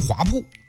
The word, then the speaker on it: 华铺: 就很多人喜欢写成三点水那个铺，但其实他这个铺，他念铺，大铺的铺，啊，正确读音华铺，它是土字旁那个铺，为什么叫这个名呢？